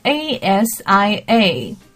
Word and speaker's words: Asia: OK，那么这一句的亚洲 A S I A，大家先想一下哈，你可以先默念一下这个词怎么念，很容易把它念成什么 Asia，Asia，OK，呃，那么有关于这个亚洲的发音应该是什么 Asia。